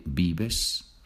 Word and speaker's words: vives: Remember, the V is pronounced B. Donde vives.